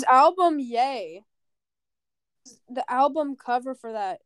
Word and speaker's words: Ye: it's yay, it's yay. I looked it up. It's pronounced yay.